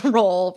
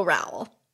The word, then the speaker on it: Rowell: And then number 10, which poor Rainbow Rowell rhymes with towel.